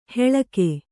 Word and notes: ಹೆಳಕೆ: heḷake

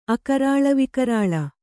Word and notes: ಅಕರಾಳವಿಕರಾಳ: akarāḷavikarāḷa

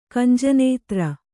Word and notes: ಕಂಜನೇತ್ರ: kannada

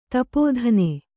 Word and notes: ಧೂಪದಾನಿ: dūpa dāni

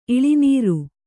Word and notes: ಇಳಿನೀರು: iḷinīru

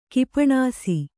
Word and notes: ಗೋಪಾನಸಿ: gōpānasi

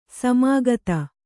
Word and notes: ಸಮಾಗತ: samāgata

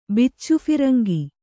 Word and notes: ಬಿಚ್ಚುಫಿರಂಗಿ: Kannada

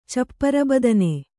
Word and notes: ಚಪ್ಪರಬದನೆ: cappara badane

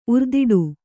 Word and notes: ಉರ್ದಿಡು: urdiḍu

